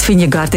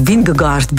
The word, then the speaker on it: Vingegaard: Ik heb nog opgezocht hoe je het moest uitspreken. – Ja, geen flauw idee. – Ik dacht Vingegaard, is Vingegaard blijkbaar.